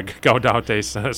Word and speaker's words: Gaudete: Gaudete? – Yeah, Gaudete Sunday.